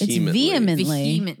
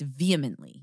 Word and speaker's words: vehemently: It's vehemently. – No, Scott. It's vehemently. – Yeah. The you don't pronounce the H like that, right? – You know what's better?